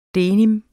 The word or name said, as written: denim